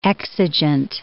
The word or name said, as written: exigent